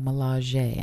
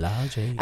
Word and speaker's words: Milaje: I keep saying it wrong, but now that I know, Milaje, I need to get into the habit of it. – Milaje.